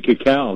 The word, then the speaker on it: cacao: Well, he'll be thrilled to know that you actually pronounced it cacao,